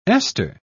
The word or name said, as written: ester